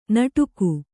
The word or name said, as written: ನಡಗೆ